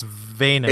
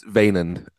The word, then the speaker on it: Wynand: So speaking of people that talk funny, Wynand, which is how I'm gonna say his name. I think it's Vaynand. – It's Vaynand. Yeah, I believe Vaynand.